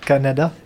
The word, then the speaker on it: Kannada: It's Kannada. – Kannada. – Da. The last one is not pronounced da. Well. – Da. Kannada. – Yeah. – Okay, good. – Like the country, basically. – It's basically like Canada, but you put more stress on K okay.